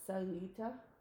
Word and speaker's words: Sayulita: And we stopped in this little town after our ATV excursion called Sayulita, right? – Sayulita. – What did I say? – You said it correctly.